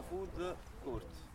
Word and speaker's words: food court: quindi una food court, la pronuncia è giusta,